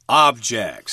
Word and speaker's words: object: But here it's the noun with the accent on the first syllable, object.